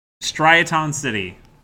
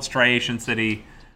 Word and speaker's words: Striaton: Striaton City? I usually call it Striation City. But that's not correct. That's not how it's spelled.